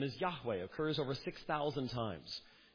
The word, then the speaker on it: Yahweh: That's God's name in the Old Testament, Yahweh. Now, in some English languages, that's slaughtered as Jehovah, but that couldn't possibly have been the way it was pronounced in Hebrew originally.